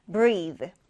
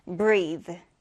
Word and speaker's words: breath: 자, 이때 명사로 숨, 호흡이라고 할 때는, 자, 이때는 모음이 에 발음이 납니다. 그리고 이때 TH도 성대를 울리지 않는 가벼운 발음으로 해서 breath, breath 이렇게 발음해 주는 거예요.